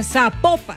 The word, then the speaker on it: Zapopan: Oiga, nos escriben, nos escriben los oyentes y que no se, no se pronuncia Zapopan, sino que se pronuncia Zapopan. – Zapopan.